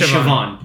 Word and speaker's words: Siobhan: It's like if you've ever seen how Siobhan is spelled, it's like Siobhan. But it's actually Siobhan.